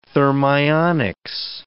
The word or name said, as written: thermionics